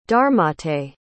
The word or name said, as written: dharmatej